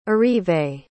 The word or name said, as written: arrivé